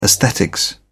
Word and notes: esthetics: British English

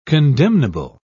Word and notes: condemnable: British English

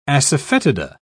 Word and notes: asafoetida: British English

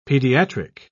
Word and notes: pediatric: British English

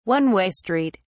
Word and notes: one-way street: British English

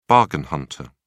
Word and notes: bargain hunter: British English

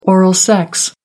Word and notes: oral sex: American English

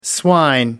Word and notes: swine: American English

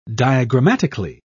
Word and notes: diagrammatically: British English

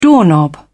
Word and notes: doorknob: British English

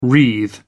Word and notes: wreathe: American English

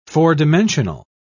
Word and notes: four-dimensional: British English

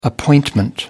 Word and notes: appointment: American English

